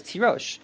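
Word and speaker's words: Tirosh (תירוש): It's written as Tirash and it's pronounced as Tirosh.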